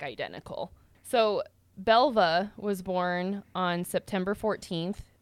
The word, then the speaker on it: Belva: Either way, Belva? – Yeah. – Belva. – Belva. B-E-L-V-A.